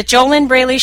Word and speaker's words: JoLynn Braley: If you're listening on some other service, if you don't see the the JoLynn Braley show's picture there, that's the one of me in the red dress.